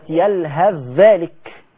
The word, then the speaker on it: يلهث ذلك: عندك كمان في سورة الأعراف كلمة يلهذ ذلك، يلهذ ذلك في سورة الأعراف، يلهذ تنتهي بالثاء وذلك تبدأ بالذال، كلمت عنها معاكم في طريق الشاطبية. ولو انت فتحت سورة الأعراف تحديدا في ربع وإذ نطقنا الجبل فوقهم، الربع القبل الأخير في سورة الأعراف، هتبص كلمة يلهث ذلك هتلاقيها بتنتهي بثاء معراة، مفيش حاجة عليه، والذال التي تلي الثاء مشددة، ده كناية عن الإضغام، وحرف معرة يلي حرف مشدد يبقى إضغام.